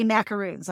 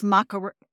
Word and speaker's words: macarons: They brought us, and I always say macaroons, and it's macarons.